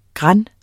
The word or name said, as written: gran